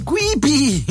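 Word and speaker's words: Kewpie: Credo si pronunci Quipi. Ditelo voi, Quipi. Si scrive Quipie.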